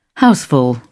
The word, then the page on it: houseful: British English